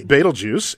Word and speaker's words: Betelgeuse: Betelgeuse. And that's how you pronounce it, Betelgeuse. – Betelgeuse.